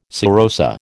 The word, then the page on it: serosa: American English